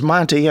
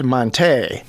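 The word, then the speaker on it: Montee: He's also decided he'll have his name pronounced as Monty and not Monte.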